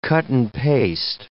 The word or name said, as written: cut-and-paste